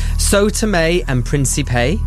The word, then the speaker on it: Sao Tome and Principe: Sao Tome and Principe.